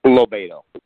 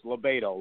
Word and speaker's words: LoBaido: Lobiedo. – Lobiedo. – That's Think of libido. Scott Lobiedo. – Exactly. Well, as an Italian, I say to my friends, it's Lobiedo, like tomato.